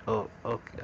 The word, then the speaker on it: OKeh: That label, it's spelled O-K-E-H. Okay. I guess that's how you say it.